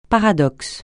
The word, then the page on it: paradoxe: francais